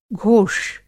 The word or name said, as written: घोष